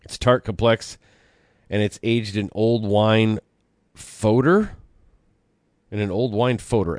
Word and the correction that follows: foeder: F-O-E-D-E-R. What the hell is a fodder? So it's actually pronounced fooder, and a fooder is basically an oversized barrel.